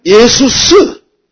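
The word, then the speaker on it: 예수스 (Ἰησοῦς): 그것을 헬라식으로 발음하면 예수스, 예수스 그렇게 발음이 된 겁니다.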